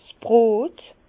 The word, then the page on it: 's Brot: Bas-Rhin; Herrlisheim